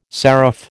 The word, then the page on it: seraph: American English